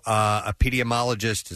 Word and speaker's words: epidemiologist: According to epidemiologist, is that how you say it? – Yeah, you said it right.